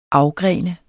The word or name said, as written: afgrene